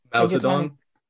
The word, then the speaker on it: Mao Zedong: Mao Zedong.